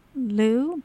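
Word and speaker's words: leu: The singular is L-E-U. I think they pronounce it Lou, but for, what do you call, more currency, they call it lei.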